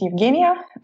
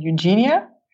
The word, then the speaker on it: Eugenia: Hi, my name is Eugenia, or you can pronounce it in a more English way, Eugenia.